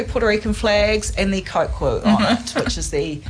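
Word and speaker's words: coquí: It's called I can never pronounce it right, a cocu, spelled C-O-Q-U-I, and it is just, yeah, this common bloomin' frog everywhere.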